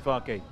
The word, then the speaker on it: Farke: Farke, are we going with that? – Yeah, Daniel Farke. Yeah. – Okay, not German. Fark. It is definitely Farke.